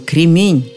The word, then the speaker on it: кремень: Знакомый-то мой кремень. Именно такое ударение в этом слове. Один кремень, нет ни одного кремня. Вокруг кремни. Нет кремней. Помета «неправильно» пресекает любые попытки передвинуть ударение с конца слова.